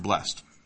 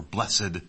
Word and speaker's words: blessed: And that is, we have, this is a recurring theme in the Psalms, is this word blessed, or blessed. We have a tendency to put an accent on that last syllable because of our hymnody.